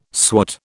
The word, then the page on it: swat: British English